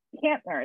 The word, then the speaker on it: Kottner: And it's Kottner. It's the German pronunciation, an end on the front and the back side of a T is Kottner.